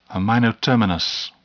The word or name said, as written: amino terminus